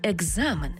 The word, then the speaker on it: екзамен: Тому слово написано через «к» і «з», а в транскрипції і у вимові будемо чути «г» і «з» – екзамен, тобто дзвінкий плюс дзвінкий.